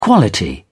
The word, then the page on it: quality: British English